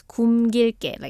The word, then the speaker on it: 굶길게: Yes, and in the third sentence, in the syllable of 굶, like the liel miem consonant, double consonants, it has to be pronounced as 굶길게, like 굶.